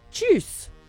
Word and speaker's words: Tschüss: Like juice with a ch. Tschüss. – Tschüss.